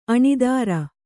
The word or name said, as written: ಅಣೂತ್ತರೆ